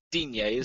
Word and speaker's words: Digne: I've gone with Luca Digne, or how do you actually say his name? – Digne, isn't it? – Digne, yeah.